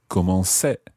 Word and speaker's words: commençais: You would spell it C-O-M-M. E-N-C with a little cedilla, A-I-S. – That's exactly right. So you put on the cedilla to keep the C sound soft. Otherwise, it would be je commençais.